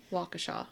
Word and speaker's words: Waukesha: And now, that is from the documentary, that pronunciation. – Waukesha. – Waukesha. So, if anybody thinks that we pronounce it wrong, this is how they pronounced it in the doc.